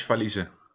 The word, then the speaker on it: Valize: Yeah, Mitch Valize.